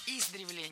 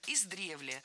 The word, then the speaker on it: издревле: Издревле, неверно. Правильный вариант – издревле. В этом слове ударение ставим на второй слог.